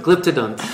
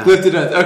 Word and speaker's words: Glyptodon: thank you for correcting my pronunciation. Wait, how do you pronounce it? – Glyptodon. Yeah. – Glyptodon. Okay, there we go.